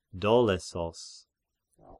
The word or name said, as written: Dolesos